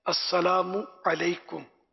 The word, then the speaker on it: السلام علیکم: بہرحال یہ کہ سلام کا تلفظ ہے السلام علیکم، میم پر پیش ہے، میم کے بعد واو نہیں ہے، لکھنے میں لوگ واو لکھتے